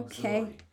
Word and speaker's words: auxiliary: Auxiliary. Like A-U-G-Z-I-L-I-A. – It's auxiliary. – Well, that's what it's spelled, but it's pronounced okay. – Auxiliary.